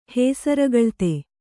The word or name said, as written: ಹೇಸರಗಳ್ತೆ